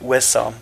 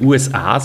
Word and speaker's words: USA: Das heißt, die Steuersituation war in Italien dazumals attraktiver als in den USA, was sicher auch ein Motiv war. – Das taugt mir, dass ihr Schweizer nicht USA sagt mit Betonung auf A, sondern USA mit Betonung auf U.